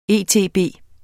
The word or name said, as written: etb